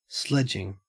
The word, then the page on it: sledging: Australian English